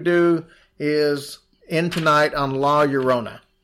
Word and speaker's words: La Llorona: It's La Llorona.